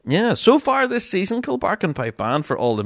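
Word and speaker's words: Kilbarchan: I still think I'm pronouncing that wrong. Is it a Kilbarkin? No, Kilbarkin is the correct way.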